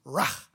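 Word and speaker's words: rah (רע): It's rah. It's just one little syllable, rah, and it's super evil, and every time anybody is said to be rah, they die.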